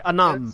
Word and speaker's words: Annam: Anam. – Spelled the same way in my notes. – A-N-N-A-M. The Allfather. A-L-L-F-A-T-H-E-R.